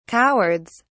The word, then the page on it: cowards: British English